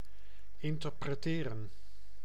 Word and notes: interpretieren: /ʔɪntɐpʀeˈtiːʀən/

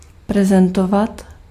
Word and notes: représenter: [ʁǝ.pʁe.zɑ̃.te]; France

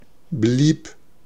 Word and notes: blieb: /bliːp/

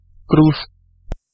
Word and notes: kruis: [kɾʌjs]

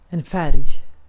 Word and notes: färg: /fɛrj/; /færj/

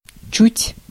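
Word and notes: чуть: /t͡ɕʉtʲ/; Russian

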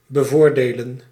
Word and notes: favor: [ˈfeɪ.vɜː]; /ˈfeɪvə(ɹ)/; /ˈfeɪvɚ/

